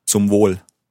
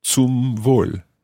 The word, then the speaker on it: Zum Wohl: You can say Prost or Zum Wohl. So let's hear the first one. Prost. Prost. Prost. And the other one was? Zum Wohl. Zum Wohl. Zum Wohl.